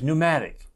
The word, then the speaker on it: pneumatic: We have that word in English, pneumatic, something that's pneumatic. It's operated by air, pneumatic tires or something like that. There's a P on the front of that, but the P is silent, like in pneumonia.